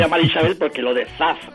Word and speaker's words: Zaz: Zaz. – Bueno, pues a mí me resulta muy difícil. – A ver, hago una zeta, no la categórica castellana, ni siquiera la S líquida.